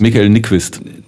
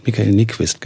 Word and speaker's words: Michael Nyqvist: Michael Nyqvist. – Michael Nyqvist, genau.